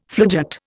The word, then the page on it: floodgate: Indian